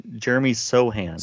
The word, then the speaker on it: Sochan: I mean, you even mentioned it with the Spurs, and you look at Wemby along with Vassell and Soch, how do you pronounce his last name? Sochan? – Jeremy Sochan.